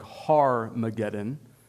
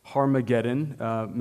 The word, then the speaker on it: Armageddon: So first of all, the most common view, Armageddon, is probably better pronounced something like Har-mageddon. Har-mageddon, many understand this to be a reference to Megiddo or Mount Megiddo, Har meaning mount or mountain in Hebrew, and then Armageddon being a reference to Megiddo.